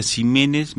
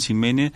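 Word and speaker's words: Ximénez: Usted, Daniel Jiménez, aclaremos, es con X, pero se pronuncia J. – Yo deformé mi apellido en el Pasteur, porque todos los pacientes me decían, cuando fui residente, yo decía Jiménez, me presentaba como Daniel Jiménez, y la gente me devolvía Jiménez.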